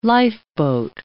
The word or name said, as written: lifeboat